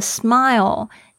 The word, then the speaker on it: smile: 这边是用的 smile。 S M I L E 这边呢，特别注意一下你们的发音，有很多的同学会不小心发成 smell，smell 是闻起来臭臭的这个味道，这个闻的这个动作，大家要特别注意一下，不要 keep smelling，好奇怪哦，是 smiling，要注意一下那个 I 的声音是 I I smiling。